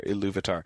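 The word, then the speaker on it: Ilúvatar: One question that people will sometimes have is where does the stress fall in Iluvatar? And it tends to be the second syllable there, Iluvatar.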